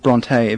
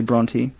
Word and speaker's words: Bronte: Some people pronounce it Bronte, but it's actually Bronte.